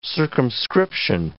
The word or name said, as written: circumscription